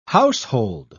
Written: household

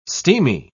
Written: steamy

